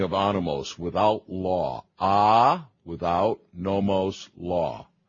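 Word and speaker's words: anomos (ἄνομος): The meaning of anomos, that's the Greek there, anomos, not anomoj, or you can't even pronounce MWJ in English, can you?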